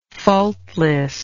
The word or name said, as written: faultlessness